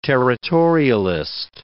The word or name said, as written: territorialist